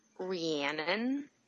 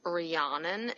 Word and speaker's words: Rhiannon: I would like to point out that my name is pronounced Rhiannon. It rhymes with Shannon because a lot of people say it's Rhiannon and it's not.